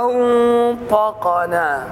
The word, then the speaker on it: أنطقنا: والخطأ هو تفخيم النون في أنطقنا، فتقول قالوا أنطقنا ما، بسبب القاف، بسبب مجاورة النون للقاف، فالقوي يطغى على الضعيف والمفخم يطغى على المرقق، فلا بد من تخليص الحروف من بعضها.